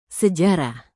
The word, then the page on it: sejarah: Indonesian